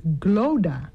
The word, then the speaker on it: Clodagh: En daar stond Glodagh. – Zo spreek je het uit? – Nee. – Oh. – Je spreekt het dus uit als Glodagh.